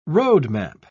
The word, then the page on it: roadmap: British English